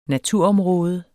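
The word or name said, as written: NATUROMRÅDER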